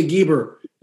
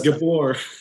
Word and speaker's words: gibor: Well, I used to jokingly say back in the early days of my youth, I'd say, hey, come on, be a geber. And I didn't realize I was saying it wrong. It's gibor. – Gibor, yeah.